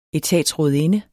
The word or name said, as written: etatsrådinde